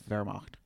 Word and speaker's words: Wehrmacht: Not to take away from the gravity of what you're saying, but in German you pronounce, you're supposed to pronounce W's like V's, so it's Wehrmacht. – The Wehrmacht.